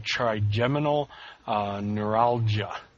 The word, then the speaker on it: trigeminal neuralgia: trigeminal neuralgia. I'm not a doctor. Hopefully I pronounced that correctly. – That sounded good.